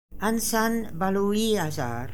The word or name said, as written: Ansanbaluiazar